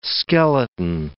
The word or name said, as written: skeleton